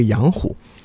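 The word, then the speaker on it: 阳货: 那孔子就为父亲守孝，守孝期间呢，这个养虎，我们知道《论语》就有一部叫养货。呃，霍在古代通假虎，就那会儿霍的读音也是虎，所以其实就是这个杨虎。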